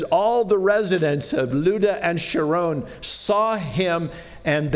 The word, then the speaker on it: Lydda: I know it looks like Lydda or Lydda, but it's actually pronounced Lydda. There's a little accent there, which I can't do.